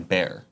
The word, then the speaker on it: Bear: I was shocked about Justin Ryan Beer's generosity and decided to shoot him a shout-out. – Ryan Bear. – Bear? It is. Beer would be spelled with B-E.